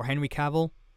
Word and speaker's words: Cavill: At once I made the prediction that Henry Cavill would come back, or Henry Cavill, depending on how many people actually say his name.